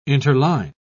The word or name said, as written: interline